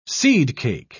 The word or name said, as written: seedcake